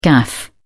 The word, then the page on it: gaff: British English